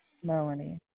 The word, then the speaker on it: Melany: M-E-L-A-N-Y, Melanie. – So it's M-E-L-A-N-Y? – Yeah. – So it's M-E-L-A-N-Y.